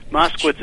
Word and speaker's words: Moskowitz: I think there's a lot of people that want that name, but Moskowitz is so easy to pronounce and spell, I think I'll keep it.